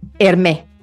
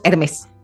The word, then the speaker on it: Hermès: Hermé. hermés, que le dicen. Hermé. – ¿Pero se pronuncia Hermé? No sabía, ¿eh? No sabía. – Porque es en francés, fundada en 1837 ah. en París por Thierry Hermès, ¿no? Ok. – Pero aquí le decimos Hermès, ¿no?